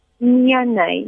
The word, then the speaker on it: Miane: Miane. – Miane, yes.